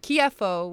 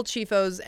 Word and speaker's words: Chieffo: But partly because Chifo, Chiefo would be the Italian pronunciation mm. of my name.